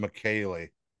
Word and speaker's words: Michele: It's spelt Michelle, but it's pronounced McKaylee.